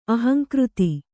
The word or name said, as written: ಅಹಂಕೃತಿ